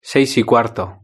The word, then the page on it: seis y cuarto: European Spanish